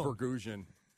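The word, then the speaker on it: Vasgersian: Or talk about Matt Vergasian. Isn't that how you say it? Vergasian? – Vergasian.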